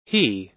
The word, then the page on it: he: American English